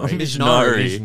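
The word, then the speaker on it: Mjnari: Yeah, and so Rogue basically is hearing, oh, this guy, Mijnani, am I saying it right? – No, Mijnari. – Mijnari, oh my God. – It's like Minari with the Mij.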